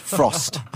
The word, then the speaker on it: Frozt: It's spelled F-O-R-Z-T, but it's pronounced Frozt.